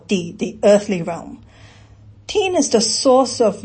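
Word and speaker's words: tien (天): So this concept, it's pronounced tien in Cantonese or tian in Mandarin.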